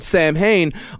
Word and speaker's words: Samhain: which is spelled Samhain, on the eve of November the 1st.